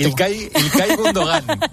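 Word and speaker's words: İlkay Gündoğan: Ilkay Gundogan.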